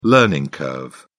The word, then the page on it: learning curve: British English